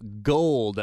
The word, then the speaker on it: GOOLLDD: G-O-O-L-L-D-D, Gold.